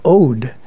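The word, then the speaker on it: od: The term odic is the adjective form of ode, pronounced like mode,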